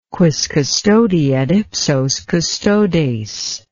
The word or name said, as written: quis custodiet ipsos custodes